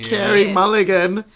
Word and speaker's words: Mulligan: And I had heard of Outside Mullinger, sizzling, however one pronounces it. – Sizzling title. mini titular role over here. – Kerry Mulligan.